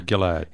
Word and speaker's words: Gilad: Have I pronounced that right, Gilad? – I guess. – Is that a soft or hard G? – No, it sounds... No, I would be a hard G, I think, yeah.